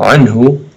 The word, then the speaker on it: عنه: So عنه, the ضمة there that's on top of the letter ها, it's as if we have to extend it, it's as if there's a wow there.